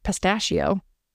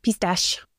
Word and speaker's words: Pistache: And her daughter, Pistache, which is like pistachio or pistachio. Is the actual way you pronounce that pistachio? – Pistache.